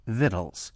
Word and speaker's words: victuals: I verified that Ronnie Lundy did hers without sugar in her extraordinary cookbook, Victuals, pronounced actually Vittles.